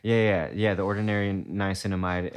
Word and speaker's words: niacinamide: The niacinamide. – Oh, niacinamide? – Niacinamide.